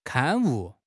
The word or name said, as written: 刊物